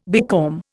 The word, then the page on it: becalm: Indian